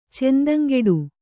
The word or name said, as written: ಚೆಂದಂಗೆಡು